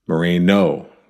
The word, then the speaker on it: Moreno: I also want to say thank you to the folks who helpfully corrected my pronunciation of Moreno. Moreno. There's no tilde.